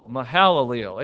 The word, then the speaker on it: Mahalalel: That second syllable, Mahalalel, it even tells you where to put the accent.